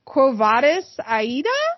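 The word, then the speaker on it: Quo Vadis, Aida?: So the nominees for that are Another Round, Better Days, Collective, The Man Who Sold His Skin, and Quo Vadis Aida? – Yes, that's how you say it.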